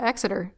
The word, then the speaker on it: Exeter: She lived in Exeter. – Okay. – I don't know if I said that right. Tiff, did I say that right? Exeter. Let us know. – I think, yeah, Exeter.